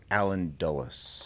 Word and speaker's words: Allen Dulles: Also, it's Alan Dulles. – Is it Alan Dulles? I'm saying that wrong. – Like the airport.